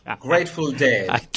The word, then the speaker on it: Grateful Dead: Grateful Dead.